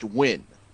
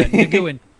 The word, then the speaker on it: Nguyen: Hey, it's a lot better than this, which I'm putting in chat, which is pronounced Win. – Yeah, Nguyen.